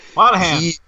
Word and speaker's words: Monaghan: But it, it was also shot down by the storyline that came out a couple of days ago now, where uh, Dominic Monaghan, or however you pronounce that fucking hobbit's name, Monaghan. – Monaghan, yeah.